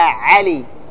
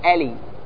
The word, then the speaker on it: علي: ينطقون العين همزة، ما يقولون يا علي، يقولون يا ألي، ينطقون العين همزة لأنه تقارب، تقارب المخرج، تقارب إيش؟